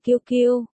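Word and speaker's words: QQ: Cái này đọc là kiêu kiêu. 1. Vô văn hóa, ba cô ở bên cạnh chỉnh họng. 1. Kiêu kiêu, mẹ Diệp Phàm đã đọc sai kiêu kiêu thành Khâu Khâu.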